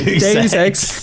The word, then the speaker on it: Deus Ex: I always say it wrong. Deus Ex. Because it's like Deuce.